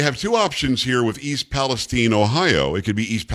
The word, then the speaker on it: East Palestine: Look, Carrie, it's not complicated that it's not pronounced Palestine. – Why is it people can't get that straight? I mean, there's the Palestine that is a country that doesn't exist and never did, where Palestinians are from, and we pronounce it Palestine.